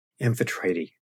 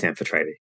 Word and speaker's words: Amphitrite: Again, Amphitrite. – Amphitrite. Okay. – People mispronounce it all the time. It's like an issue, but it's Amphitrite.